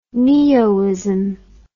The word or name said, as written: neoism